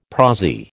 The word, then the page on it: prosy: American English